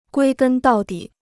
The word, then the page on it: 归根到底: guī gēn dào dǐ